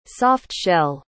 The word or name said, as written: soft-shell